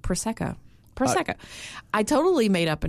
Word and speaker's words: Prosecco: And finally, one of these very kind people said, you're mispronouncing it. It's Prosecco.